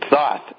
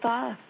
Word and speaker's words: Thoth: Thruath introduced... – When Thoth, that's Thoth, actually. – That's Thoth.